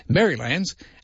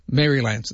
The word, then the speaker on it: Marylands: Emmanuel said, also, well done to Dusty for pronouncing Marylands the Aussie way. Marylands.